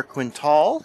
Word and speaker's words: Quintal: I'd like to introduce Miss Cassandra Quintal.